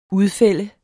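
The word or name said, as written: UDFÆLDEDES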